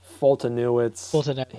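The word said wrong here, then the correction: Foltynewicz: He's okay. Or Fultonevich, yeah. I always say his name wrong. It's a W, but it's pronounced V.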